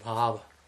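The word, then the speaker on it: bhāva: So bhava is material existence with short A, and from that we go to the long A with bhava.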